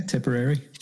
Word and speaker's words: Tipperary: Is that how you say it? – Yeah, Tipperary.